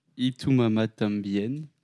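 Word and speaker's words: Y tu mamá también: Enfin, il n'y a pas longtemps, j'ai regardé le film euh, Itumama Tambien.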